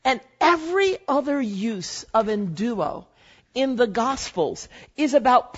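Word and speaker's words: enduo (ἐνδύω): Evato. Evato is what, how do you say it? – It's enduo. – Enduo. Thank you.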